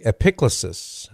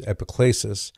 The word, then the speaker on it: epiclesis: So the epiclesis, that's one way to pronounce it. The other way is epiclesis. But however you pronounce it, it's the part of the Mass just after the offertory as the priest is moving from the offertory into the Eucharistic prayer.